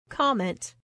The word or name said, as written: comment